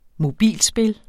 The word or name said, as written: mobilspil